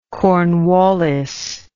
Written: Cornwallis

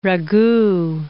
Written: ragout

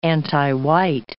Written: antiwhite

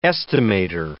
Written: estimator

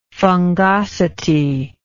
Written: fungosity

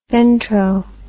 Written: ventro

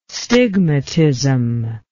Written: stigmatism

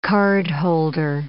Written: cardholder

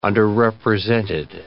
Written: underrepresented

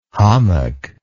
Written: hommock

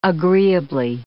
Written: agreeably

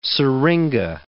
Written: syringa

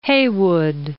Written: Heywood

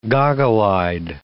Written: goggle-eyed

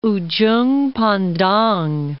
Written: Ujung Pandang

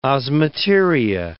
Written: osmeteria

